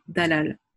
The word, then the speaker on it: Dalal: C'est drôle parce que en fait, avant cette année aux Beaux-Arts, je disais mon prénom comme ça s'écrit. Toute mon enfance, toute ma jeunesse, on m'a appelée Dalal. Parce que mon prénom s'écrit D-A-L-A-L.